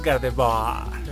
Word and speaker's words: Gardevoir: ¿Gardevoir o Gardevoir? Es Gardevoir. Obviamente, caballero.